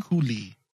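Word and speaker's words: Kuiil: Is that how you say his name? – Cooley.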